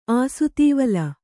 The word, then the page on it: ಆಸುತೀವಲ: āsutīvala